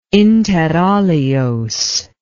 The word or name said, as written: inter alios